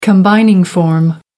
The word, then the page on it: combining form: American English